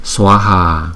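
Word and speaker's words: Svāhā: Cho nên chữ này chúng ta đọc là Swaha, Swaha. Chúng ta đọc rõ ra là Swaha. Chữ S và chữ V đi chung chúng ta đọc là Swa, Swa, Swaha, Swaha. Thì đọc nhanh thành chữ là Swaha.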